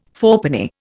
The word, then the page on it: fourpenny: Indian